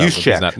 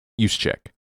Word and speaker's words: Juszczyk: He's not Juszczyk. – Not eligible. Juszczyk.